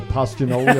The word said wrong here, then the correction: Castagnoli: or whatever. – Castagnoli.